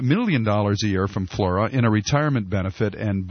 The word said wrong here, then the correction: Fluor: And I think it would be, say, spell that again for me. – Fleur? – F-L-U-O-R. Fleur. – Fleur. I like that a lot better.